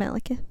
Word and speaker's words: Malekith: Malekith, is that how you say it? – Yeah, mm-hmm. Malekith. – Malekith.